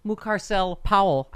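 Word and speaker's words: Mucarsel-Powell: Representative Debbie Mucarcel Powell.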